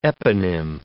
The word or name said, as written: eponym